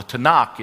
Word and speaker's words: Tanakh: The Tanakh is how it's said.